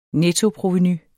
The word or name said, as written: nettoprovenu